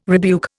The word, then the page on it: rebuke: Indian